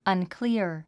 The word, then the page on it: unclear: ʌ̀nklíə r; アンクリア